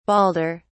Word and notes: balder: British English

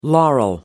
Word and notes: laurel: American English

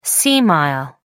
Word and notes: sea mile: American English

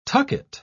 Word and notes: tucket: British English

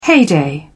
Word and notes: heyday: British English

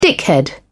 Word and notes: dickhead: British English

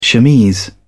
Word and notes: chemise: British English

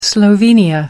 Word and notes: slovenia: American English

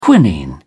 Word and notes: quinine: British English

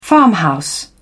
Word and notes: farmhouse: British English

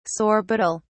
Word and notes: sorbitol: British English

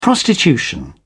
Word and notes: prostitution: British English

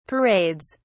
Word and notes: parades: British English

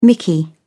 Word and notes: mickey: British English

